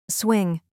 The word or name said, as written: swing